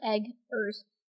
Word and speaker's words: Eggers: It's spelled like Eggers.